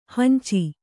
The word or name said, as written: ಹಂಚು